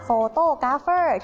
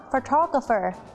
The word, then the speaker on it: photographer: ใช่แล้วค่ะน้องๆชอบออกเสียงผิดนะคะเราจะไม่พูดว่า photographer ที่แปลว่าช่างภาพเราจะออกเสียงว่า photographer